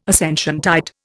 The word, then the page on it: Ascensiontide: Indian